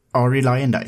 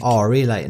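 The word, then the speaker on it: Arie Luyendyk: Bland annat så smäller ju till mellan AJ Foyt och du får uttala det. – Ari Lyndike. – Ari Lyndike. Det har ju blivit väldigt amerikaniserat. Ari Lyndike där.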